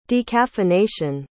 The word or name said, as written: decaffeination